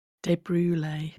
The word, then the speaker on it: De Brule: De Brule. – Yeah, she wrote De Brule. She broke it down and she said it rhymes with creme brulee.